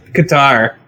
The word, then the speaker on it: Qatar: Is it pronounced Qatar? Because I always pronounced it Qatar. – Yeah, it's Qatar. – I think it's Qatar. – Qatar, dude.